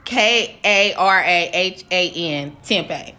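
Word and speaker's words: Karahan Tepe: Karan Tempe. But whatever. It's Karahan Tempe. Tepe.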